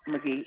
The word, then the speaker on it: McGhee: C-O-N-C-H-A-T-A. – C-O-N-C-H. – A-T-A. – A-T-A. Conchata. And your last name is? – McGee. M-C-G-H-E-E. – M-C-G-H-E-E.